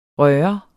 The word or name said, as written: røre